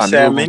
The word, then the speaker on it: salmon: It was nice seafood, crab, shrimp, and some salmon. – Crab. – You know, and you know what's dope? I didn't pronounce the L in salmon.